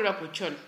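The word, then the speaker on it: bakuchiol: Vamos a empezar por el bacuchol, porque es un término nuevo, así como hasta cuesta pronunciarlo, es bacuchol en español.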